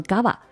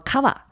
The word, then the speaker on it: Cava: 我们西语会读作 Gava，但大家可能比如说你用英语去读，就是读作 Cava，或中文翻译成卡瓦。